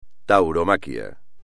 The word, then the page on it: tauromaquia: espagnol